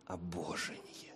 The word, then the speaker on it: Обожение: Ударение на второй слог. «Обожение».